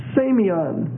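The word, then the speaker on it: semeion: The word signs, also there in verse 19, is semion, S-E-M, that's a long E, so S-E-M, E-I-O-N, S-E-M, E-I-O-N, semion.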